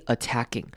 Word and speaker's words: attacking: Then someone starts swearing and personally attacking. Personally, 这边也要注意，注意它重音在第一个音节 personally. 好，然后中间的 O 我们会念成轻音 personally. And personally attacking. Attacking, 注意到是它一开头的 A 呢，这边念轻音 attacking. 那中间的 A 则是念短母音 A 的声音 attacking. 所以重音是放在第二个 A 上面 attacking the intelligence.